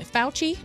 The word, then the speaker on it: Fauci: Anthony Fauci.